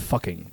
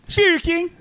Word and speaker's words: Fucking: We have spoken about strange city names before, and we've talked about the Austrian village of fucking, which is not how they pronounce it, I guess. – Fuking.